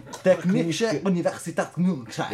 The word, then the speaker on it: Technische Universität München: Lo puoi dire un po' più tedesco la cosa? – Dove stanno? Technische Universität München. – Grazie. – Pronuncia tedesca di quelle madrelingua, cioè figlio di diciamolo.